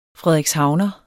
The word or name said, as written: frederikshavner